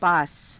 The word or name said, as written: paasa